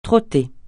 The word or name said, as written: trotter